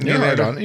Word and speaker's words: Nylander: Nylander. Is that how you say it? – Nylander.